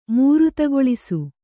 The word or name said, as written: ಮೂರುತಗೊಳಿಸು